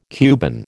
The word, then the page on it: Cuban: American English